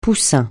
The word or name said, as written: poussin